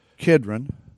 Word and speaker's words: Kidron: He went forth with his disciples over the brook Kidron. That's the way it's pronounced.